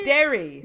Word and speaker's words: Derry: I would like to point out that if you're fans of Derby Girls. – No, not Derby. – How do you say Derry. – Derry! Gosh, Derry Girls.